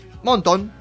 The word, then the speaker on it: Montón: Montón, porque con acento en la O final.